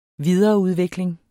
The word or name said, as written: videreudvikling